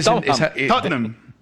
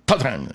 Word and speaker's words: Tottenham: Tottenham. Tottenham. Son dos sílabas. – Es difícil, ¿no? – Nosotros los británicos. – ¿Solo dos sílabas? – Sí, Tottenham.